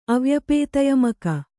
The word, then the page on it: ಅವ್ಯಪೇತಯಮಕ: Kannada; avyapētayamaka